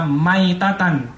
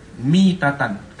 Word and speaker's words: mitatan: Beliau mengatakan bacanya itu bukan mata maitatan, tapi bacanya mata mitatan. Kasroh di sini, bukan fathah di sini.